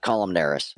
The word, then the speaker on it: columnaria: I can send you that word. Make sure I'm not butchering it. – Columnaria. – Thank you.